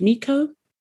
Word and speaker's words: Meiko: I think her name is pronounced Miko. M-E-I-K-O.